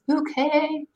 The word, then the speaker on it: Bucket: It's a show about this woman who, her last name is spelled B-U-C-K-E-T Bucket, but she insists that it's Bouquet.